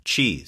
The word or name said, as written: cheese